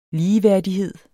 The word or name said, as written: ligeværdighed